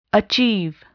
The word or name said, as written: achieve